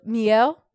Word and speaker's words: Mielle: Miel.